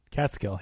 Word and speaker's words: Catskill: Catskill, yeah.